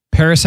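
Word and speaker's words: Pvris: I'm putting these all in the 2020 mix, but Paris has a new song. It's called Use Me. Oh my gosh. So good. It's like your alternative kind of electro pop style. – Paris spelled Pavris.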